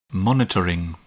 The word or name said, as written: monitoring